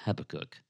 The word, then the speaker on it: Habakkuk: And by the way, when in doubt, pronounce it quickly, and it sounds like you know what you're doing, but it really is Habakkuk.